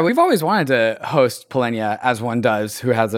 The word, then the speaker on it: Polynya: Polynya is a crypto anon. David and I have called this individual Polynya in the past. Turns out that is not how you pronounce Polynya's name. It's Polynya. We did this conversation in kind of a unique way.